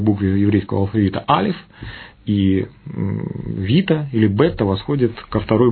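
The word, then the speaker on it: вита (βήτα): В разных прочтениях вот это бета, она читается как вита, поэтому алфавит.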